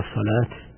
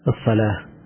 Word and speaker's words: الصلاة: نعم أي أربعين أربعة حي على الصلاة حي على الفلاح حي على الصلاة، بعض الناس يقف عليها تاء حي على الصلاة حي على الصلاة، معروف أن هذه التاء أصلها الهاء، فإذا وقف عليه يقال الصلاة،